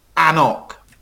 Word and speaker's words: Annock: So just a couple of ones with lots of consonants is A-N-C-N-O-C. – A-N-C-N-O-C, yes. And so that's Annock. – Annock. – Right. Annock. Annock, yes. It's from International Beverage, which is formerly Inverhouse. Lovely whiskey. Do peated versions. Nice style of whiskey. But it's pronounced Annock.